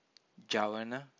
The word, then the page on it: javana: Pali